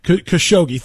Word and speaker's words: Khashoggi: Khashoggi, thank you.